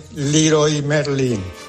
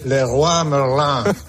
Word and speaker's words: Leroy Merlin: A ver, Herrera, que no es Leroy Merlin, que es francés y se dice Le Roi Merlin.